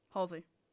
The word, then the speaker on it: Halsey: Halsey.